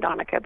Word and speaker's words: Donica: Donica before. And my father's name is Don, so I was named after my father, Don Aka. And so it's spelled kind of like Monica with a D.